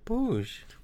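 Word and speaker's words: puž: So puj rhymes with muj, and muj is a husband. – Oh. – So you got to make sure you say pujespor, not mujespor, right? So pujespor, the snail is slow.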